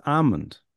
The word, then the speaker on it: almond: Almond. You don't pronounce the Almond. – L. It's almond.